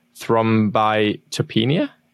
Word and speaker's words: thrombocytopenia: And has been medically classified as a VIT, vaccine-induced thrombotic thrombocytopenia.